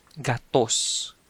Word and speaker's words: gatos: So the word for 100 in Cebuano is gatos. Gatos. That's G-A-T-O-S.